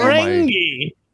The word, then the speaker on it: Ferengi: We have Federation Cult, the Romulans King, and the Ferengi Gaz. Did I say that right? Because I'm not, I don't Ferengi. – Know my. – A Ferengi. Whoa, who said that? – Mandingo McThickens says you're the Ferengi.